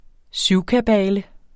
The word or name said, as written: syvkabale